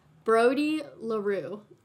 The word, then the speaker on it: Brody Larue: And this is awesome because this one was recommended to us by Brody Larue. – I don't know if I said that right. I pronounced it You said it right. You said it right. It's totally French because he's from Canada.